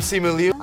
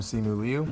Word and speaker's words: Simu Liu: A correct pronunciation of his name. – Hi, I'm Simu Liu. – I'm Simu Liu.